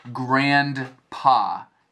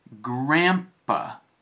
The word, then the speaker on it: grandpa: It's not grandpa. It's grandpa, almost with an M sound.